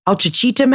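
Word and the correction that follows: Ouachita: Mountains? I don't fucking know. – Where? Wachita. – Wachita? – Yeah.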